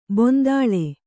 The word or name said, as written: ಬಾಂದಳೆ